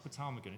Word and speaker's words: ptarmigan: A rock ptarmigan. It's called the Thunderbird. How do you pronounce it? Ptarmigan. You don't pronounce the P.